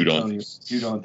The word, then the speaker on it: Judon: Sure, up outside linebacker with Matt Judon, I don't know how to say his name. – Judon, thank you.